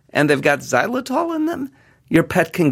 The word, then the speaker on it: xylitol: It begins with the letter X, but it's pronounced xylitol.